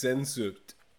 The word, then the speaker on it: Sehnsucht: It's *Sehnsucht*.